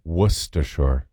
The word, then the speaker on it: Worcestershire: S-T-U-H, Worcestershire, S-H-R. – It's very underrated. – That's the pronunciation. – Worcestershire. – Worcestershire. – So you don't say the R, you don't say Worcestershire? – No. – Worcestershire. – Worcestershire. – Yeah, I'm going to go. – How often do you even use Worcestershire sauce? – Not very often.